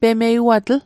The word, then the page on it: pemeiwatl: Mi'gmaq